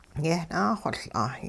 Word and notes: yehnaaxoL'a: hupa

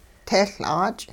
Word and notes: tehL'aach': hupa

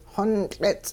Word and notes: xontL'its': Hupa

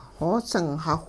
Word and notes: xoosingxawh: hupa

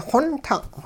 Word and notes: xontung': hupa

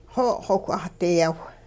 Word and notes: xo' xowh 'ahdiyaw: Hupa

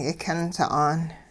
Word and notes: yikyun sa'an: hupa

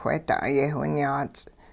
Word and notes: wheeda'ay-yehwinyaats': hupa

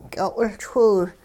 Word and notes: ky'o'wiLchwiw: hupa

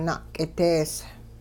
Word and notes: na'k'idees: Hupa

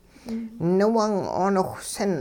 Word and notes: niwung-'ooniwhsin: hupa

